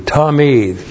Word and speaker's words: tamid: And then the adverb tamid. T-A-M, long I-D, tamid.